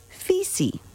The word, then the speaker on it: Fisi: However, when British navigator Captain James Cook first encountered Fijians in neighboring Tonga, he heard the Tongans, not the Fijians, the Tongans, pronounce the island's name as Fisi.